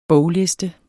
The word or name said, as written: bogliste